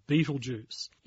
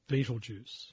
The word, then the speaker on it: Betelgeuse: The name itself, Betelgeuse, it's a mispronunciation of its name that's been handed to us throughout the years, through the Dark Ages to the Middle Ages to the Renaissance, we've basically picked up an interpretation of the Arabic pronunciation, Abd al-Yaza.